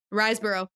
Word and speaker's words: Riseborough: But the actors include Christopher Abbott, Andrea Riseborough, is that how you say her name? – Riseborough, yeah. – Riseborough, Jennifer Jason Leigh, and Sean Bean.